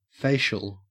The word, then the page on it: facial: Australian English